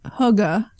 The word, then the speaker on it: hygge: But this is all related to hygge. Did I say it right? – Yeah.